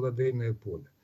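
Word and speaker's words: Лодейное Поле: Произносить «ладейное», а не или «ладейное»? – Я слышал от жителей этого города, что они произносят именно так, и что это, дескать, правильно. Поэтому на всякий случай, чтобы не обидеть их, я произношу так. Хотя мне привычнее, конечно, сказать «ладейное поле». И когда мы обсуждали, мы, конечно, говорили вот с мягким «ладейное поле».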